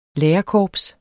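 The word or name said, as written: LÆRERKORPS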